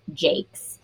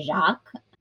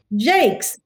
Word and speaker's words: Jacques: in which they actually have in parentheses pronounced Jakes. Because I was nervous getting ready to record. I was like, I don't know. I was going to say Jacques.